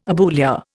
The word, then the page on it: abulia: Indian